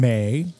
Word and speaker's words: May: We had a wonderful server named May, mm-hmm. – and she said, like the month. – So, makes me think it's spelled M-A-Y. I know M-A-E is a common spelling of that name.